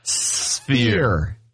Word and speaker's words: sphere: So this sphere. No, I said it again. I said it wrong again. – Sphere. – Sphere. Like fear with an S. Sphere. – Say sphere. – Sphere. – F-E-A-R.